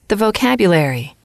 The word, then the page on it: vocabulary: female